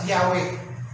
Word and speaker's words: Yahweh: Pag sa Bible nyo, nakita nyo yung capital letters, N-O-R-D, capital letters, the Lord. Sa Hebrew language, Yahweh yan, yung Y-H-W-H. Sinalin sa English, yun ang bigkas, Yahweh.